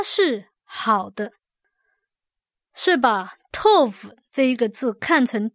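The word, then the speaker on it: tov (טוב): 我们今天要看的字是 tov，好这个字，它是好的，读音是 tov。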